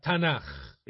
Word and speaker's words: تناخ: بالنسبة لليهود هم ما بيسموش كتابهم المقدس العهد القديم, بيسموه تناخ. فهنا أدي تناخ, التاء من توراة, والنون من نفييم, والكاف من كتوفيم, لكن لما بتيجي في آخر الكلمة بتتنطق خاء, فيبقى تناخ.